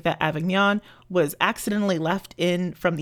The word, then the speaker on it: Avignon: But she also has Colonel Brandon's presumably English sister living in Avignon. I probably butchered that. – Avignon. – Sorry. It's Avignon. French.